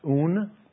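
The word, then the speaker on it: un (οὖν): And it's a Greek word that is pronounced "un."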